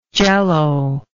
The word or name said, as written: jello